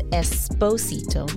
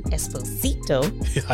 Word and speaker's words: Esposito: Esposito. I was looking up. I wanted to make sure I said it right. So he says it's Esposito, not Esposito.